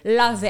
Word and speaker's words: Lave: If you want the French pronunciation, it's Lave. – Lave. – And it means wash, but here in America, we call it Lave.